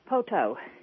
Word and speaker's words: Poteau: Is that how you say it? Is it Poteau? – Poteau. – Poteau. Okay, Poteau, Oklahoma. – P-O-T-E-A-U.